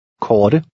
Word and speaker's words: korte: The T sounds like a cross between the English T and D. – Korte.